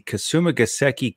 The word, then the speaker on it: Kasumigaseki: So if I can just pronounce the course correctly, it is the Kasumigaseki Country Club, the East Course.